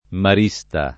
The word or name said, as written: marista